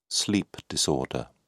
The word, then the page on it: sleep disorder: British English